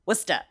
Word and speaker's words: Worcester: Yeah, like every letter in Worcester is silent. So the bumper sticker would be Worcester, where the only thing silent is every single letter in Worcester except for the W and the stir.